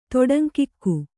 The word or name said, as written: ತೊಡಂಕಿಕ್ಕು